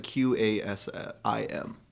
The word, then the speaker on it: Qasim: Q A S I M. – Q A S. – Yeah. Because in Arabic, it's Qasim.